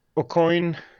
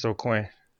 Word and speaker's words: Aucoin: Yeah, it's Aucoin, and then if you're French, it's Aucoin.